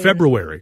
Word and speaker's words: February: February, because the R comes before the U.